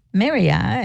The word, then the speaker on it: Merja: And that's Maria, it's spelled M-E-R-J-A, Sumoloff.